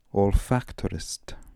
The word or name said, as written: olfactorist